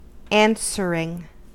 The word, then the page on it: answering: American English